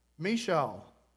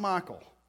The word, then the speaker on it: Michal: How's it? Michal. It's not Michael.